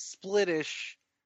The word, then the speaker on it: splittish: What you're saying is splittish rhymes with British.